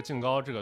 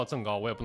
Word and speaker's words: 甑糕: 赠其实如果用纯普通话来讲的话，这个东西应该叫赠糕，但是陕西人、西安人把这个东西念净糕，这是因为我我当时查了，它有一个就是关中话，关中话保留了一个古音，这个净它后来就是有一个音变变成赠了，但是就西安这边把这个净糕这个读音还保留下来了，所以西安人这边都叫净糕，但是你非要叫赠糕，我也不能说你错，但是入乡随俗嘛，对吧？